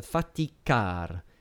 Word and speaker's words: faticar: So faticar has an accent on the final line.